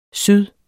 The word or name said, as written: syd